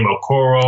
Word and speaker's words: Okoro: Okoro or something.